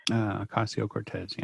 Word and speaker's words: Ocasio-Cortez: Like all of the attacks on Ocasio-Cortez, am I saying her name wrong? – Ocasio-Cortez, yeah.